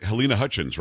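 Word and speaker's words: Halyna Hutchins: Alec Baldwin's being charged with involuntary manslaughter in the death of, I want to say it right, Helena Hutchins, right? – Yes, that's right.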